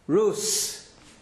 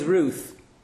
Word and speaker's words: Ruth: The sof, the nearest I've got to the sof is a th. Th. Th sound. Rus was known correctly as Ruth.